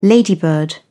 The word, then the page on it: ladybird: British English